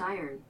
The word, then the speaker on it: iron: That's pronounced iron. – Iron.